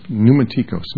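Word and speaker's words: pneumatikos (πνευματικός): The word in Greek is pneumatikos, P-N, but pronounced pneumatikos, pneuma, like the word pneumonia, same root.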